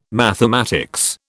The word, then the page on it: mathematics: British English